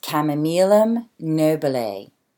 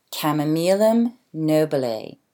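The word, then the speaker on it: Chamaemelum nobile: So to begin with, the Latin binomial is pronounced chamomilum nobile, chamomilum nobile.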